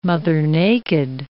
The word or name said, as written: mother-naked